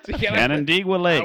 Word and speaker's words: Canandaigua: Canandaigua. – I heard Jew Lake. – Canandaigua Lake.